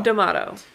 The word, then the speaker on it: D'Amato: Or it was directed by how do you say this one? – Joe D'Amato. – DeMonto? – Joe D'Amato? – D'Amato.